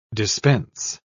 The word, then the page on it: dispense: American English